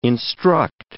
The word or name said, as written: instruct